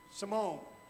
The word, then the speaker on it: Simone: Simon Biles, a young Olympian. Simone, you're right, it is Simone. I wasn't even looking at it and said it wrong.